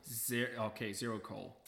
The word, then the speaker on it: Xerocole: Zero coal.